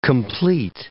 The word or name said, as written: complete